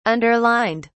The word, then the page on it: underlined: British English